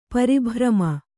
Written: ಪರಿಭ್ರಮ

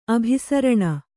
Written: ಅಭಿಸರಣ

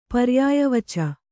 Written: ಪರ್ಯಾಯವಚ

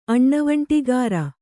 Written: ಅಣ್ಣವಂಟಿಗಾರ

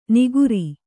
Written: ನಿಗುರಿ

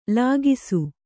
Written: ಲಾಘಿಸು

